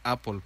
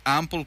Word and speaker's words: Ample: Ojo, porque aquí de repente la pronunciación podemos decir que es Apple, pero no. De hecho se escribe Ample. Entonces Ample Cash.